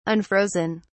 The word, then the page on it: unfrozen: British English